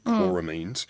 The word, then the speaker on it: chloramines: Well, apparently urine and other contaminants, as I put, icky things our bodies bring in, binds with chlorine, and you get these chloramines, I think I'm pronouncing it correctly, chloramines. – Yep.